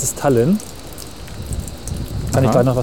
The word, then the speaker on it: Tallinn: spricht man das Tallinn, so? ja. Tallinn. Die, die, Deutschen sprechen das wohl so aus, die Estländer glaube ich anders.